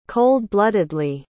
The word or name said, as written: cold-bloodedly